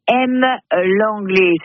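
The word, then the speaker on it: Hem-Lenglet: Demain, à M, l'anglais, ça s'écrit H-E-M, trait d'union, L E-N-G-L-E-T.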